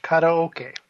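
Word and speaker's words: karaoke (カラオケ): If you pronounce it like it's Spanish, karaoke.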